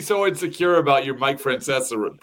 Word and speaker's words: Francesa: Say his name. – Mike Francesa? – No. No, you said it right.